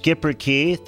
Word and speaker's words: Schipperke: It's pronounced Schipperke.